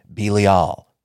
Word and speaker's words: Belial: I know people pronounce this one every which way, but my research thus far has led me to Belial.